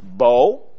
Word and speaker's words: bow: The word B-O-W can be pronounced bow, when used as a weapon, Genesis 27, verse 3. And it can mean, and it can be pronounced bow. Very same, the same spelling, B-O-W in both cases. Pronunciation is completely different.